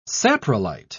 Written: saprolite

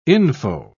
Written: info

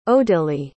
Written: odilie

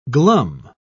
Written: glum

